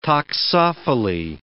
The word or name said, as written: toxophily